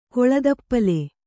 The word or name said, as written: ಕೊಳದಪ್ಪಲೆ